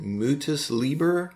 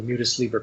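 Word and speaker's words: Mutus Liber: In the appendix, you have the plates, these images from the, is it Mutis Liber? – I think that's the way it's pronounced, yeah.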